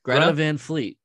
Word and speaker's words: Greta Van Fleet: Greta Van Fleet.